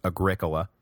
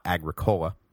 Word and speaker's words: Agricola: One possible side consequence of Domitian's mock triumph was that Agricola, and yes, thank you for the pronunciation correction, it is Agricola, not Agricola, which, yeah, I agree, sounds like some kind of terrible hippie soda, so I'm sorry about that.